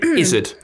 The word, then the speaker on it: izzard: Izzard. – So you spell zoo, Izzard-O-O. – No, it's spelled like blizzard without the BL. I-Z-Z-A-R-D.